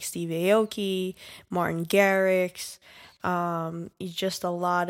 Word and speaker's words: Aoki: Yeah, I read an article somewhere that Steve Aoki said, I hope I'm pronouncing his name right. – Yeah, you are.